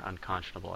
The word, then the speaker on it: unconscionable: Just for grammatical perfection here, unconscionable, I think, is the way to pronounce it. – Thank you, unconscionable.